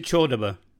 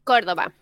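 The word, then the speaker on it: Córdoba: She comes from, is it Cordoba, or do you pronounce it Chordoba? – Cordoba. – In Cordoba.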